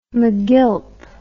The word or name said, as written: magilp